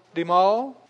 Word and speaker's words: DeMaul: That is how to pronounce Magic DeMaul. You like going to the mall? DeMaul? Like going down to the mall?